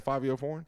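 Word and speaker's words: Fivio Foreign: Yeah, Favio Foreign. But it's spelled like Fivio.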